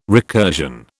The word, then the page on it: recursion: British English